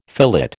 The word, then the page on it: fillet: American English